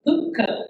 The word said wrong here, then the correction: pukeko: No, not Pukko.